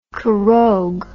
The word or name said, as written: Khorog